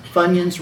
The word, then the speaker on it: Funyuns: Funyuns rhyme with bunion.